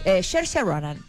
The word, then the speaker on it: Saoirse: Y está protagonizada por la actriz irlandesa Saoirse Ronan, que se escribe Saoirse Ronan. Lo digo así porque la gente cuando lo lee, incluyéndome, la reconocen, pero el nombre se pronuncia muy distinto como se... – Yeah. – Entonces la busqué para pronunciarla bien.